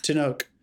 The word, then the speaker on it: Tenoch: Tenoch. – Tenoch.